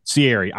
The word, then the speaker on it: Sieri: I like, if you can pronounce his last name, is it Sieri? – Sieri.